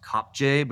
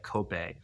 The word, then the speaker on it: Kopje: It looks like it's pronounced Kopje, but it's like Kope, Elsa's Kope in Meru National Park.